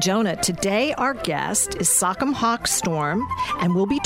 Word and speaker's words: Sachem: It's pronounced Sachem.